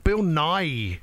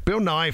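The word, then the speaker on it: Bill Nighy: But Bill Nye. – Yes. – Is that how you say it? – Yes. – I've never known how to say it. Bill Nye from Love Actually.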